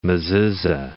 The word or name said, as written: mezuzah